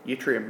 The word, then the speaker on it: yttrium: It's like there's a chemical called yttrium, which is Y-T-T-R-I-U-M, and it's pronounced yttrium. Y-T.